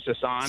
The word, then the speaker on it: Sasan: My name's Sasan. – Sasan. Okay. – And, it is Sasan. Go ahead. – Yes, it's not Hassan with an H. – Right, we heard. Okay. – S-A-S-A-N.